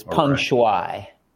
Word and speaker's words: Peng Shuai: Before we get to the matter at hand, tell our listeners, if you would, sort of a background, Peng Shuai, and tell me if I'm pronouncing that wrong. – It's Peng Shuai.